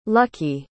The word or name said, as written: luckie